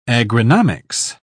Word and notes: agronomics: British English

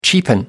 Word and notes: cheapen: American English

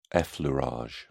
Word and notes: effleurage: British English

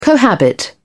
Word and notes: cohabit: British English

